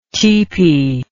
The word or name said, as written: tipi